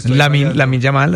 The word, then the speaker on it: Lamine Yamal: Es que yo creo que Lamine Yamal, así se pronuncia.